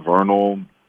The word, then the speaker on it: Vernal: Oh, they can follow me at just my name, Vernal Coleman.